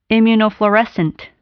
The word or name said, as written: immunofluorescent